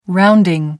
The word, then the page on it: rounding: American English